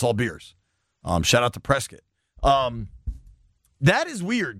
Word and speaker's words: Prescott: Prescott is how they pronounce it.